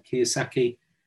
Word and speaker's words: Kiyosaki: Um, it would be Rich Dad Poor Dad, the, the uh, Robert uh, Kiyosaki. That's the correct pronunciation.